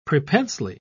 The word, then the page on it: prepensely: British English